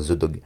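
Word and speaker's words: Zeudog: Alors The Dog, ça s'écrit Z-E-U-D-O-G, à la française quoi, The Dog.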